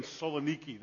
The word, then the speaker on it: Saloniki: And the reason we chose that as a title is because when the folks who lived in Saloniki, that's how it's pronounced today in modern-day Greece, Salonika, Thessaloniki, those folks came to faith, it cost them.